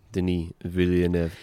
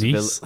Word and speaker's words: Denis Villeneuve: Denis like spelled like Denis. Denise. – Let's just say how it's written. Denise. – The wickedly talented Denise. – That's it. No, Denise from now on.